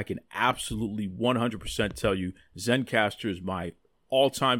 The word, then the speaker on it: Zencastr: And the reason why is because I am using Zencastr. Now, let me spell that for you. Z-E-N-C-A-S-T-R, Zencastr.